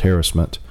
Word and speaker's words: harassment: I used to call it harassment, but then the media says, no, it's pronounced harassment.